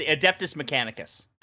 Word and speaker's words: Adeptus Mechanicus: It's the Adeptus Mechanicus.